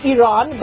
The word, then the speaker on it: Iran: And so I will be calling it Iran. That really is how you pronounce it.